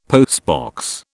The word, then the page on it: postbox: British English